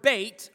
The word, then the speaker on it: Beit: The word Beit, or Beth as it's pronounced here in our text, is the word for house.